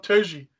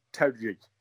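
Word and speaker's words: Terje: Todd Turgey. – Right. So it's apparently it's supposed to be pronounced Todd Terry.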